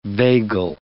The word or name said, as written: vagal